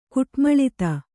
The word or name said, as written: ಕುಟ್ಮಳಿತ